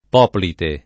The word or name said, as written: poplite